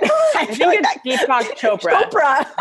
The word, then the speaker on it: Deepak Chopra: And the quote is from Deepak Chopra. Did I say that right? – Deepak Chopra.